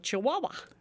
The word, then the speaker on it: Chihuahua: We, along with South Carolina, searched Google for the correct spelling of Chihuahua. – Well, I'm sure I was leading the charge in that how to spell. C-H-I-U-A-H-U-A? – You almost got it. The last two syllables are identical.